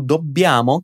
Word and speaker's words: dobbiamo: Perceba que o dobbiamo, que você vai pronunciar já já junto comigo, tem dois Bs, por isso vamos dar uma reforçada na hora de pronunciar.